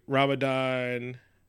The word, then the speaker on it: Ramadan: And so every other president would have a Ramadan. You said it right.